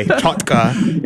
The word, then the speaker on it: chotki: it's spelled C-H-O-T-K-I. Chotka. It's kind of pronounced kind of funny.